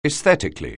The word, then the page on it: aesthetically: anglais